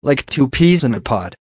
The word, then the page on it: like two peas in a pod: American English